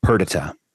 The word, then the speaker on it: Perdita: Perdita. – Um, at least that's how the Brits say it.